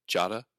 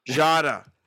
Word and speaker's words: Jota: You mean Jota? – Jota. Yes, sorry. I mispronounced it again.